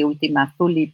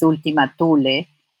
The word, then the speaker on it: Thule: Y por eso nos pareció, ¿no es cierto?, apropiado que se le llame última folie, porque se le, en inglés la TH se, pronuncia a veces como una Z, entonces se dice última folie, pero es última tule, eh, ¿no?